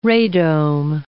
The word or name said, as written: radome